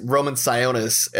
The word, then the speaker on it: Roman Sionis: And, you know, like, I like that Victor Zsasz, or not Victor Zsasz, Black Mask, Roman Sionis, as his proper name is pronounced, is actually emotional in this film.